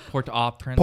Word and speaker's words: Port-au-Prince: Port-au-Prince.